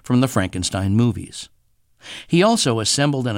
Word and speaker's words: Frankenstein: Dr. Frankenstein. – Frankenstein. – You're putting me on. – No, it's pronounced Frankenstein.